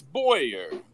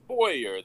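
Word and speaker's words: Bowyer: It's Boyer. Boyer. The W is silent. – Oh, okay. Boyer. – Boyer, yeah.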